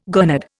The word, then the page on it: gurnard: Indian